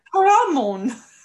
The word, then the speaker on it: Caramon: That's not how you say it. It's Caramon.